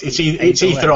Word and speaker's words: aether: No, it's Aether either way, yeah. But that A-E together like that is pronounced E.